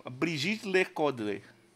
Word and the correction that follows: Brigitte Lecordier: Alguém sabe francês? A minha pronúncia? – Não. – Então, Brigitte Lecordier. Ah. – Eu fiz francês, acho que tá certo isso daí. Brigitte Lecordier.